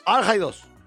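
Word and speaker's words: Al-Haydos: Al-Haidos. – Es Hassan Khalid Al-Haidos. Así es la pronunciación.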